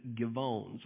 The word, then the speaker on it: Gibeon: It looks like Gibeon is what it looks like in our Bible, but I looked it up, and a man online said I should pronounce it Givon.